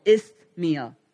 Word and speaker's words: Isthmia: there's a city called Isthmia. Okay, I don't have a lisp. It's spelled weird. I-S-T-H-M-I-A. Isthmia.